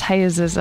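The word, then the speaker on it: Jesús: Actually, it's pronounced Hayes's, I think.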